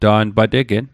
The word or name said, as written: ta'n pa tegen